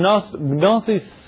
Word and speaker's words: Gnosis: One Gnosis is G-N, like a gnome.